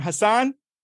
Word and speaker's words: Husnain: Today we're here with, how do you say your name, Husnain? – Husnain.